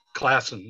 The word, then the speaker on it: Classen: Classen, yeah.